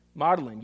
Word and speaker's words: Magdalene: Is it Madeline? Madeline. You don't say the G.